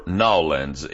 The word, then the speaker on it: New Orleans: That's Willie Nelson on Billy Country, 96.3 FM, AM 1350 KTLQ in Tahlequah, 93.3 FM in Fort Gibson, or Nawlins if you're in Louisiana. That's just how they say it.